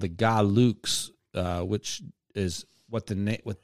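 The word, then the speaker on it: Gahluks: No, it's G A H L U K S. But that's the phonetic. – He didn't know how to spell it. I was like, Galuks.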